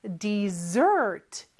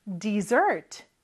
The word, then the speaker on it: dessert: 영어로 디저트라고 하는데 발음이 조금 달라요. 그리고 스펠링 잘 보세요. S가 두개 있습니다. dessert. 이렇게 뒤에 강세가 있고요. 같이 한번 따라해 볼까요? dessert. dessert. 네, 그리고 S 발음이 Z 발음이네요.